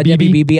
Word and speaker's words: Debbie Beebe: Should Debbie, am I saying her name right? – Yeah, Debbie Beebe.